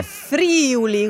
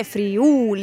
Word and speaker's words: Friuli: O dire Friuli con l'accento sulla prima I. No, è Friuli.